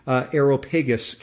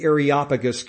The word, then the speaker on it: Areopagus: At the time, Paul was in the Greek city of Athens, and he was in an area known as the Areopagus Council, sometimes pronounced the Areopagus Council, but more correct would be the Areopagus Council.